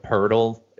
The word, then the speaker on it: Poeltl: I know the Jakob Poeltl is always a tough one because it's pronounced with an R. You know, there's no R.